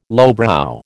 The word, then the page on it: lowbrow: American English